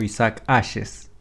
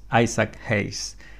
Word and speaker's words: Isaac Hayes: La principal fue la de Barry White, pero también estaba Isaac Hayes o Isaac Ashes, se escribe así, pero es Isaac Hayes.